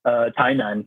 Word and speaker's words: Tainan: That's how you say it, Tainan? – Tainan, yeah. – Tainan. – Like T-A-I-N-A-N.